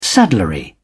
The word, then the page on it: saddlery: British English